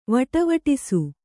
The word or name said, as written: ವಟವಟಿಸು